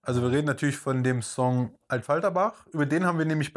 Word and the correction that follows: Affalterbach: Ja gut, dann halt, was, wie Affalterbach? Af, Alter. Genau, so glaube ich, kann man es am besten aussprechen, weil okay. ein A, Doppel F. Ja, Af Alterbach.